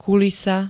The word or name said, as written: kulisa